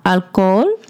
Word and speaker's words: alcohol: You don't pronounce the H in the middle, right? So toma alcohol. – Mm-hmm. – So it's like you just forgot the H. Toma alcohol. – Yeah, the H is silent.